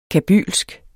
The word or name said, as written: kabylsk